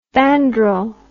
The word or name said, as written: bandrol